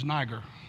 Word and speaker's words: Niger: And in the Bible translation, the word is spelled N-I-G-E-R. I, for years, pronounced it as Niger. It's not pronounced properly. It's actually a French pronunciation. It's Niger.